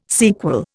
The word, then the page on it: sequel: Indian